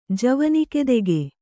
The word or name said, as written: ಜವನಿಕೆದೆಗೆ